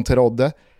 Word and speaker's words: Terodde: Jag har noterat att målsprutan från Zweite Bundesliga, Simon Terodde, eller Terod, jag vet inte, du får hjälpa mig där. Jag har aldrig känt att du har övertygat mig i det tyska uttalet. T-E-R-O-D-D-E.